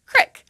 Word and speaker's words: creek: For example, instead of the long E sound, as in creek, some people in Appalachia pronounce this word as crick, with an is sound, as in the word tip.